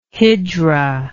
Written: hijra

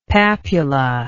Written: papula

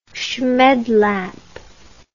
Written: schmedlap

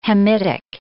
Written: Hamitic

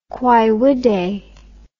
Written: quaevide

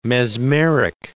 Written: mesmeric

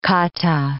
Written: kata

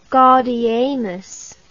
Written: gaudeamus

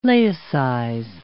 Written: laicize

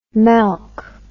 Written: melk

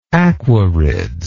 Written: Aquarids

